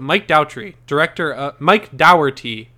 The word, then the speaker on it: Dougherty: I don't know if we ever talked about this, but Mike Dougherty is how you say it.